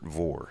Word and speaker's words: Vore: In the studio with somebody new, a new face around here at Northside Church, Robert Vore.